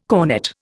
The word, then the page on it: cornett: Indian